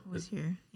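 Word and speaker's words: Hozier: But I remember, I don't know, but she was touring, you have to remind me, Hoser. What's the guy you was touring with? – Hosier, yeah. – With who? – Say his name right? – Hosier. – Hosier.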